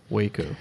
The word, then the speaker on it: Waco: I think it's pronounced Waco. – Well, the reason I say wacko is because way back in the day, there was a wacky situation.